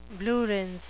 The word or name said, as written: blue rinse